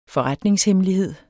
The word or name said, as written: forretningshemmelighed